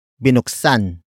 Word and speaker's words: binuksan: Word for word it's binuksan nila ang tarangkahan. Binuksan nila ang tarangkahan. Binuksan nila ang tarangkahan. The first word is binuksan. Binuksan. Binuksan. Binuksan is spelled as B I N U K S A N.